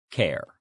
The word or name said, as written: care